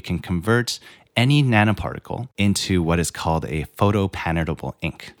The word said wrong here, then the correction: photopatternable: And what does this photopatentable, am I saying this right? – Patternable. – Yeah, photopatternable. Okay, ink.